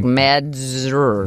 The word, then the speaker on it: Mazdzer: It's Mazdzer, so it's like Mazur.